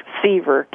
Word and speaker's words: Sever: And your last name is spelled S-E-V-E-R. – Yes, Seaver, K-Seaver, yes. – Uh-huh. K-Seaver, K-A-Y-S-E-V-E-R.